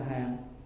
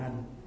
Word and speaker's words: hàng: Đó là hàng, chứ đừng đọc là hành, còn đừng đọc là hạnh.